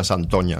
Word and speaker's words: Santoña: Y es una guía me costó entenderlo porque ponía, bueno, Saint-Oigne, como algo así, como un francés de la época escribiría algo que le han dicho que se pronuncia Santoña, ¿no? – Claro.